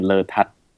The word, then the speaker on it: Lertad: The very Thai way to pronounce it would be Letad. So the emphasis on the tat.